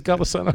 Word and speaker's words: Golisano: I was like, is it Golisano? It is Golisano.